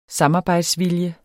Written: samarbejdsvilje

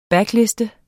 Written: backlist

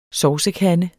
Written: SOVSEKANDE

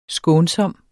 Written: skånsom

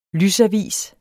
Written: lysavis

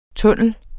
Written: TUNNELLER

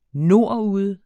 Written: nordude

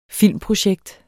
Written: filmprojekt

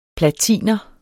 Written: platiner

